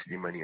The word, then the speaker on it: Slimani: Slimani. – Et votre nom de famille, ça s'écrit comment? – Slimani Mouloud. Nom de famille, Slimani, S-L-I-M-A-N-I.